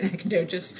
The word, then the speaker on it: Nacogdoches: Nacogdoches. That's how I've heard it anyway. There could be some Texans out there that is like, "No, it's not," but I always say Nacogdoches.